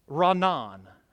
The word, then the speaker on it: Ranan: The next word is, it's spelled R-A-N-A-N. Ranan. Ranan. And it's actually used for crying out or shouting for joy.